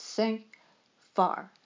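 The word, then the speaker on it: sans fard: It means unpretentious, and it's spelled S-A-N-F-A-R-D, but it's pronounced sans far.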